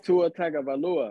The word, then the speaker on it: Tua Tagovailoa: I'm going with Tua Tagovailoa.